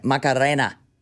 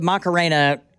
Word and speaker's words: Macarena: Macarena. Macarena. I'm going to have that in my head all day long. – You don't have to roll your R in that Macarena. – Word.